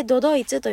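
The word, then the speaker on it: ど ど い つ (都々逸): で、どどいつと読みます。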